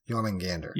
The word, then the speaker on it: Jormungandr: Yes, Jormungandr.